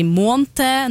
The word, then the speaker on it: måned: At noen sier månte, noen sier månt.